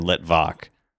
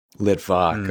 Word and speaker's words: Litvak: It's actually Litwack is closer to the original pronunciation, which it would have been Litvak. – Litvak, okay.